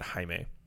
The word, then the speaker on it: Jaime: because there was a pitcher for the Cardinals years ago, and his name looked like Jamie, but it pronounced it Jaime.